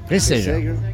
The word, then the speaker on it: Reisegger: Reisegger. – Reisegger. – Yeah. We have the soft G.